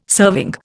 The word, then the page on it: serving: Indian